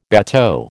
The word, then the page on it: bateau: American English